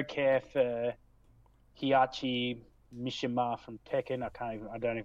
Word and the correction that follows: Heihachi: Oh, Heihachi, yeah, right. I think he's like Heihachi.